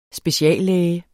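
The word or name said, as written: SPECIALLÆGEN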